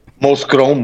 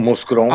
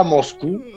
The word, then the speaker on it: Mouscron: Mire, yo cuando, cuando llego a Mouscron, Bélgica, que es donde usted está, y a lo mejor usted ahorita pronuncia bien el Mouscron, pero yo... lo... Mouscron, Mouscron. – Ajá, Moscron. Contarle que no diga Moscú.